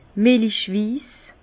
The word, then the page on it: melichwiss: Bas-Rhin; Herrlisheim